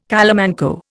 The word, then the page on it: calamanco: Indian